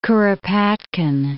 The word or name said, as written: Kuropatkin